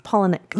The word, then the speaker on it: Palahniuk: Never know how to say his last name. – Chuck. – I meant to say Chuck, but I said Czech. Chuck Palahniuk? – Palahniuk. Look at me, like, correcting you how to say someone's name.